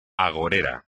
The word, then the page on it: agorero: espagnol